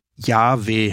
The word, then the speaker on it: Yahweh: In der richtigen Aussprache muss es wohl Yahweh heißen, je nachdem, wie stark man das H aussprechen möchte.